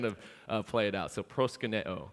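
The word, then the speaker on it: proskuneo (προσκυνέω): And it's the same Greek verb, and it's pronounced proskuneo.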